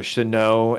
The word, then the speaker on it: Shenault: And for those people who don't know, DJ Chark, Laviska Shenault, or Chenault, as some people are starting to call him, I guess that's the proper pronunciation.